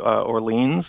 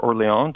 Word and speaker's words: Orléans: I played Orleans or Orléans, depending mm-hmm. on how you want to pronounce it.